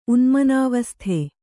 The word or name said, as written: ಉನ್ಮನಾವಸ್ಥೆ